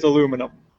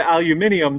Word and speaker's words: aluminum: Well, I know that it's I know that the correct way is aluminum. But I routinely pronounce it aluminium just for the fun of it, because that's a lot more fun to say. – Actually, aluminium is the old English way and how they pronounce it in other parts of the world.